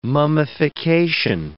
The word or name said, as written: mummification